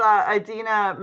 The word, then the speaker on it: Idina: So yet again, you had Idina. I'm saying it wrong. It's Idina Menzel.